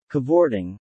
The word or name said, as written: cavorting